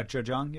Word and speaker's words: Zhejiang: In the last part of your book, you travel to Zhejiang province. Did I say it right? – Yeah, Zhejiang, yeah.